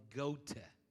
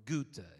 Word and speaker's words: Goethe: I got to pronounce this right. I looked this up, and last night I made sure. Johann Wolfgang von Goethe or Goethe, if you want German, is a individual that was a writer, a poet, and a politician.